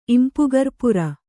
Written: ಇಂಪುಗರ್ಪುರ

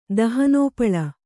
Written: ದಹನೋಪಳ

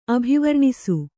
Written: ಅಭಿವರ್ಣಿಸು

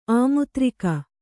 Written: ಆಮುತ್ರಿಕ

